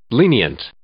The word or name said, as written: lenient